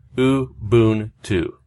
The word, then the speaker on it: Ubuntu: You hear it pronounced several different ways, like Ubuntu and other things, but its pronunciation is Ubuntu.